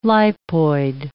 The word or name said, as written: lipoid